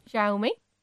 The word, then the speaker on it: Xiaomi: Other than better camera and slightly bigger battery, is there any difference between the, oh God, I can't pronounce that, XOEME 14 and XOEME, is that what it's pronounced as? – Xiaomi. – Okay, Xiaomi.